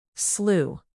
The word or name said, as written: slew